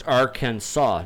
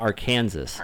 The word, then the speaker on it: Arkansas: It must be pronounced Arkansas, not Arkansas.